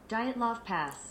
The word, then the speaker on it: Dyatlov Pass: This is what Google says. – Dyatlov Pass. – Dyatlov. All right, I already pronounced Dyatlov.